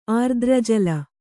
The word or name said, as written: ಆರ್ದ್ರಜಲ